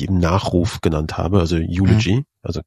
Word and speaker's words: Eulogy: Viele von diesen Fotos haben den Hashtag Eulogy, ist das richtig ausgesprochen? Mhm.